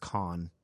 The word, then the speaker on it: Kahan: So Noah Birkenkamp Kahan, or I'm sorry, it's pronounced Kahn. It's spelled K-A-H-A-N, but pronounced Kahn.